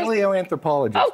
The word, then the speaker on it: paleoanthropologist: Paleoanthropologist. – Okay, paleoanthropologist.